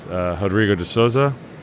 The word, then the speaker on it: Rodrigo de Sousa: Okay, so this is Drew, and I'm here with Rodrigo de Sousa.